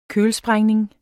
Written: kølsprængning

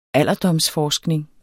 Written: alderdomsforskning

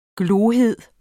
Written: glohed

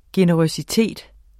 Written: generøsitet

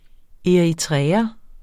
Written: eritreer

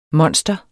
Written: monster

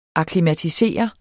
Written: akklimatisere

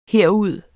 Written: herud

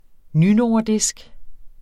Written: nynordisk

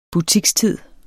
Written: butikstid

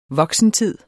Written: voksentid

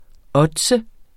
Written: ODDS

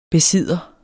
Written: besidder

